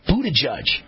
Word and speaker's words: Buttigieg: I call him Pete Butthead, but I think it's, how do you pronounce his name? Pete Buttage? Buttigieg.